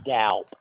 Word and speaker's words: Doup: Tom Doup, D-O-U-P. – And I'm still here, just for one moment. Okay, will you spell that last name? – Yes, it's Doup, D-O-U-P, like in soup, only with a D.